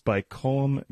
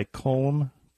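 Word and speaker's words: Caimh: It's by Colm. It's C A I M H. I know it's Irish. You hmm. should know how to say it because they often say it.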